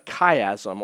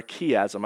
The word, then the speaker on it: chiasm: Here's a second feature we need to talk about before we get into chapter 3, and that is what is called a chiasm or a chiasm. I've heard it pronounced both ways.